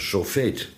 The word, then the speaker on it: sofeet: Wij kennen het als, met een heel andere uitspraak, wij kennen het als een sofeet. S-O-F-E-E-T, sofeet.